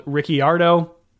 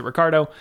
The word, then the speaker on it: Ricciardo: You may hear him called Ricciardo, because that's how his last name is spelled, but he pronounces it Ricciardo, or his nickname, the Honey Badger.